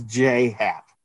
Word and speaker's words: J.A. Happ: It's pronounced. – It's just J. Yeah. – Not J.A. It's J. Happ.